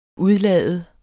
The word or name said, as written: UDLAD